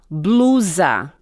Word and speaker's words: blusa: Dessa blusa. – Dessa blusa. – Blusa. Right? Yeah, like Z.